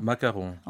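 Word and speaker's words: macaron: 마카롱.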